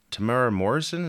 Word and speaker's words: Temuera Morrison: Temuera Morrison, is that how you say his name? – Yeah.